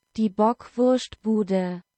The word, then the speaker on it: Die Bockwurstbude: And in German, according to Google Translate, it's pronounced Die Bockwurstbude.